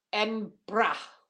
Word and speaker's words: Edinburgh: Edinburgh. That's how it's pronounced in Scotland. I was very firmly corrected when I was there.